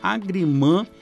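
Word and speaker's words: agrément: Agrimã, que é a palavra de origem francesa, que se escreve agrement, então é, mas você fala agrimã como échanté, mademoiselle, monsieur, carrefour, e um monte de coisa que a gente vai incorporando, né?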